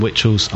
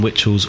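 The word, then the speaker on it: Witchalls: If you search for me on Facebook, that's Alan Witchells. I'll spell it in a second. I'm also on Twitter. My username is Alan Witchells, all one word, and this is where I'll spell it.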